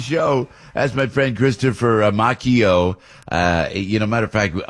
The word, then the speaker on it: Macchio: So I said, well, I might be able to get Chris Macchio, and Macchio is the way he pronounces it.